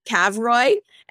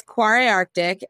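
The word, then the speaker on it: Kvarøy: It's spelt funny because it's an Arctic brand, but it's K-V-O-R-Y. So initially I was calling it Cavroy and they were like, nah, that's not how you say it. But it's Quarry Arctic and they sell to Whole Foods.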